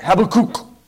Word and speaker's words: Habakkuk: Habakkuk. Okay, let's try that one. Habakkuk. When you find it, raise your hand. Habakkuk. Habakkuk, Habakkuk. Or how they pronounce it? Habakkuk. Get some phlegm in your throat.